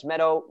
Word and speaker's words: Medow: I'm at Lance Medow. Last name is, of course, M-E-D-O-W.